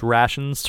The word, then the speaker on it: rations: side note, I just wanted to say I love the way that Guy pronounces rations to rhyme with station.